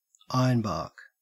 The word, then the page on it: ironbark: Australian English